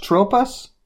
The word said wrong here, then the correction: Trope: It's Trope. – Trope. My mistake. – Yeah. Very French sounding.